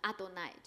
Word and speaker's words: Adonai: 但是呢，以色列人他们为了不妄称神的名，他们就把这个四个字呢加上他们的原音，然后呢就读成 Adonai，就是主的意思。